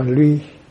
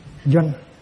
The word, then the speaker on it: doanh: Chữ này không nên đọc là ly, nên đọc là doanh. Chữ này đọc là chữ doanh.